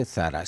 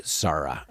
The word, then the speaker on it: Zara: Y de repente dice, no se dice Zara, se dice Zara.